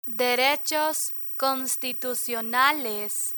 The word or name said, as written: derechos constitucionales